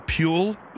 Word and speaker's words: Puell: Carl Puhl? – Yes. – And how do you spell that last name? – P-U-E-L-L.